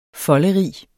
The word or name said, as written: folderig